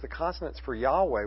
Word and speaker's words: Yahweh: And so now most scholars believe that the proper pronunciation of the word is Yahweh, though there's no way to know what the proper pronunciation is, because all you have is four consonants.